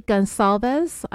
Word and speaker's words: Goncalves: Kaylee Gonsalves, I was pronouncing it wrong in the original episode that I did on this case back in January. It's with a soft C in the middle of her last name, and I was saying it with a hard C.